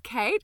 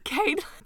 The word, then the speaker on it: Kate: And they're like, can you spell that? And I was like, yeah, K-A. And they're like, S-E. And I was like, no, Kate. Like, I ended up saying, Kate, that's a true story.